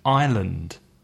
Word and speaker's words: island: And another word which I hear mistakes very often is with the word island, isla, because island has an S, mm-hmm. I-S-L-A-N-D.